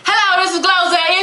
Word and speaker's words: GloZell: Glozell.